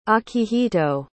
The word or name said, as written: akihito